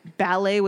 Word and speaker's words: ballet: Which, I'll be honest, the first time I saw this episode when I was in grade 2, I didn't know that ballet was spelt like that. I was like, what's ballot? – Ballot? – Yeah. I didn't, I had no concept. So this episode taught me that the T is silent.